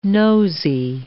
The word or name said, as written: nosy